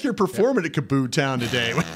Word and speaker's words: KAABOO: All right. Kaboo. I said it right, didn't I? – Kaboo.